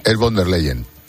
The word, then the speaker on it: von der Leyen: Bueno, yo creo que yo estoy bastante de acuerdo con lo que ha dicho la presidenta van der Beyer, si lo he pronunciado bien, que soy incapaz de pronunciar ese apellido. – Pues no, es von der Leyen.